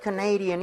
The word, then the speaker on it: Canadian: 왜냐면 캐나다는 Canada 이렇게 가는데 네. – 이게 캐나다 사람 이러면은 Canadian. 강세가 뒤로 하나 밀려요.